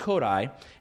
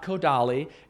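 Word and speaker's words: Kodály: It's pronounced Kodai. It's not Kodali. It's not Kodai. It's not Japanese. Okay? It's Kodai. It's like code and I. Stress on the first syllable, right? We don't do that in English. You know, we don't put the stress on the down. We put it on the ah. But not in Hungarian.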